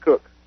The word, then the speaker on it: Koch: It spelled like K-O-C-H, but he's spelled pronounced. – Oh, yeah. – Oh, Blake. – Yeah. – You know Blake. – You know Blake. – Yeah, we didn't say his name like that. – No. – How'd we say his name? – Blake Coke. – Coke. Yeah. Yeah, or he pronounced it Cook.